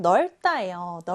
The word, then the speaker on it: 넓다: 그 다음 이번에는 좁지 않은 것 바로 넓다예요. 넓다는요 받침 리을 비읍 중에서 리을 비읍에서 리을 받침이 소리가 납니다. 그래서 널 이렇게 소리가 나고요. 다가 아니라 따 넓다라고 읽어줘야 합니다.